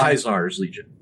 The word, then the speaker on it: Caesar's Legion: The one, Kaisar's Legion. – Kaisar, thank you.